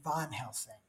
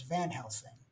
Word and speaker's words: Van Helsing: But with him, so I don't know if you noticed this, but Sean, he pronounces Van Helsing's name Von Helsing. – Yeah. – Right? Which I've always thought was kind of weird because he's usually known as Van Helsing.